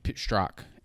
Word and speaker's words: Strzok: Joe Biden when when Joe Biden was Peter Stork, Strzok, S T hmm. R O Z K. I've been saying it wrong this whole time.